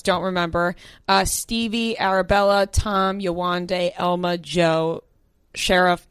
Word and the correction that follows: Sharif: and Callum. Callum? – Sharif.